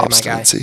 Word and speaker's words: obstinacy: God, I hope I said that right. If not, I'm so sorry, obstinacy. My guy. There's no second N. Obstinacy.